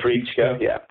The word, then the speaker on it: Treitschke: Treitschke, yeah. That's a very interesting part of the book, his use of a relationship with the concept and German power. – Exactly. I mean, Treitschke